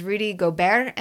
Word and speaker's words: Gobert: and that was Rudy Gobert. And I actually looked up his name. It's not the same pronunciation as Robert, which we talked about a couple of weeks ago.